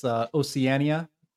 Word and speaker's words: Oceania: Well, first of all, I just want to point out that it's Oceania. So the fact that you couldn't say that properly proves that you were not AI or AI assisted as a host.